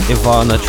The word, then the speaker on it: Ivana: She says it's he says it's pronounced like Ivana Trump. Yes, I do know how to say Ivana.